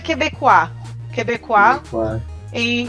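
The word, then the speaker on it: Québécoise: Em português é Quebecois. Em francês é Quebecoise.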